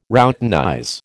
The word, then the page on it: routinize: American English